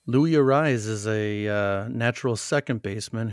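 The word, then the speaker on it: Luis Arráez: He does the rolling of the R's with a double R. So when they announce him, they say Louis Arraez. So that's the right way to pronounce his name.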